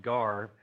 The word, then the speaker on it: ger: It's actually pronounced gar, but it's spelled G-E-R.